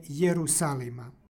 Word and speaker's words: Jerusalima: stihu Jerusalima. Deseterično i stoji na početku reči iza njega je vokal. I ovde, prema ekvivalentu u srpskom jeziku, izgovaramo je na početku, dakle Jerusalima.